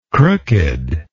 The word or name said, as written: crooked